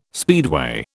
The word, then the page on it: speedway: British English